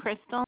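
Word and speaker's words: Krystal: My name is Crystal, and you can send the information over digitally or by email, whatever works easier for you.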